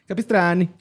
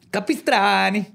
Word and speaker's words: Capistrani: ¿Cuándo vas a aceptar que pronuncie tu apellido como italiano, Borre? – Es italiano mi apellido, güey. Está bien que lo pronuncies así. Capistrani. Capistrani.